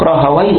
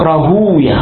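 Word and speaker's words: راهويه: طبعا راهوي تنطق راهوي وتنطق راهوية, أهل اللغة يقولون راهوي, علماء الحديث يقولون راهوية, واضح؟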